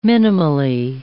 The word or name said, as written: minimally